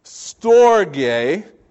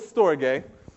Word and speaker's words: storge: The first one is storge. It's like stork, but not really, honestly. Like I don't know what was up with the Greeks and their like spellings and pronunciations, but this one is storge.